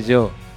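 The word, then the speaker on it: Giò: No se dice Gio, se dice yo.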